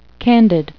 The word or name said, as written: candid